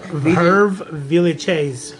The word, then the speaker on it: Hervé Villechaize: It starred Ricardo Montalban as the mysterious Mr. Rourke and Hervé, how you pronounce that? – Hervé Villechaize. – Villechaize? – He was French. – Villechaize.